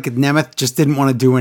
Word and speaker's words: Nemeth: Nemeth.